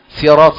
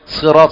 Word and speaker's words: صراط: سين سيرات صحيحة، صراط صحيحة، زراط صحيح، فهو حرف صاد لكن النطق هكذا، يعني قال هذا اشمال.